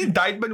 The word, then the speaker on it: indictment: So is an indictment when you're having like it's gonna sound it's not pronounced indictment though. It's indictment. Okay.